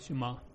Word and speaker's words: Shema: Aquí la Shema, o Sema, ¿cómo se dice la palabra? – Shema.